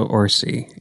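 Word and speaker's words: Orci: Kurtzman, and is it Orsi? Is that how you pronounce it? – Yes, Roberto Orsi. Yes.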